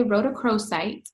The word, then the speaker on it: rhodochrosite: I'm going to say rhodochrosite.